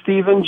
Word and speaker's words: Stephen: Yes, my name is Stephen Jaron.